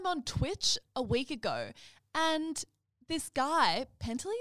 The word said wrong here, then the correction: Pestily: is that how you say his name? – Yeah, Pestily. – Pestily? Yeah. Pestily, sorry. Is getting 100K concurrents.